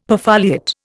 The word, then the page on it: perfoliate: Indian